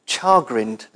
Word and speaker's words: chagrined: At the end of a very busy Thursday afternoon, I was chagrined. It's an unusual word. Is that how you pronounce it? Chagrined. Yeah, but that might be a Glaswegian accent there.